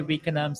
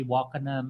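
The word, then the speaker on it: Wakenaam: Some people say Wickenham. Some people say Wakenham.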